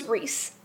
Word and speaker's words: Rhys: I know that Rhys, when it's shortened, becomes Reese. Like, I know that that's how she pronounces it.